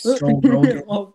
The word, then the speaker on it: Joe Rogan: Anyways, Joe Regan, sponsor us, please. – Joe Rogan.